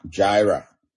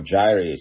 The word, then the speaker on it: Jireh: That's actually Jehovah Jireh, or Jireh. Some people call it Jireh, some people call it Jireh, but it's spelled J-I-R-E-H, Jireh, or Jireh.